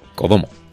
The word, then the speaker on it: kodomo: El Komodo. – Codomo. Codomo, perdón. Codomo. Codomo, ¿eh? no, es dragón de Komodo.